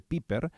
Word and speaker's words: Pieper: Es interesante este punto, les recomiendo nuevamente el libro Introducción a Santo Tomás de Aquino de Joseph Pieper, se escribe, se dice Pieper,